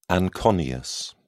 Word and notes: anconeus: British English

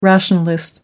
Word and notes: rationalist: American English

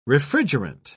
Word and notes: refrigerant: British English

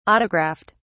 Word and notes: autographed: British English

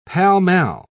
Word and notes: pall-mall: British English

